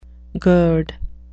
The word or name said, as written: gird